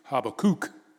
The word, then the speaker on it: Habakkuk: Some people call it Habakkuk, and Bible scholars say the correct way to say is Habakkuk, Habakkuk.